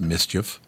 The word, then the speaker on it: MSCHF: I got it uh, we assume it's from the brand Mischief, M S C H F, pronounced Mischief.